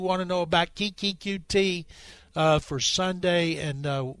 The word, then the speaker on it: Coutee: But Coutee did say it's Coutee. It's like the I letters guy Coutee. asked him yesterday, and he said Coutee.